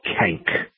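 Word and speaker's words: Canque: I'm going to spell it. It's C-A-N-Q-U-E. It's called Kank.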